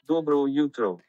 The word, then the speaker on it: Dobro jutro: Dobro jutro.